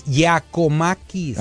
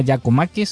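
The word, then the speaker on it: Giakoumakis: Bueno, pues, ahorita están, acaban de firmar a Juan Cuamaquis, ahorita ya lo firmaron. – Es Giacomaquis. – Ah, Giacomaquis. – Sí, así normal. Giacomaquis.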